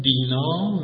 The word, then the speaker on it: دینا: بعد تو تلفظ پهلوی و باستانش میشه دینا و دینا، دینا.